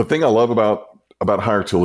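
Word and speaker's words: Hiretual: Is it pronounced Hire Tool or yes. Hire Tool? – Hire Tool. – Tool. They just can't spell that shit right.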